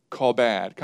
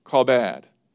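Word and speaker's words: kabod (כבד): The Hebrew word for honor is kabod, kind of like crawdad, kabod. And it's spelled in English, it's transliterated with K-A-B-E-D. Doesn't look like call bad, but that's how you would pronounce it, call bad.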